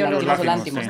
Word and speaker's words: Yorgos Lanthimos: Y, sí, ya todos, pero me gusta mucho Yorgos Lanthimos, ¿cómo se dice? – Yorgos Lanthimos, ¿no? – Sí, sí.